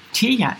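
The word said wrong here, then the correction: Tejn: Äh, ja, Tein eigentlich, glaube ich, ausgesprochen.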